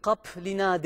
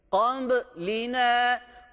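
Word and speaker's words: Kambı lina (قبلنا): Burayı da P gibi okumuyoruz. Kap lina değil. Kambı lina, kambı lina diye okuyoruz.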